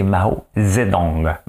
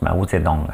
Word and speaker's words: Mao Zedong: C'est Mao Zedong. Ben, on le prononce Mao Zedong.